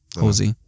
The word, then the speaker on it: Halsey: Halsey.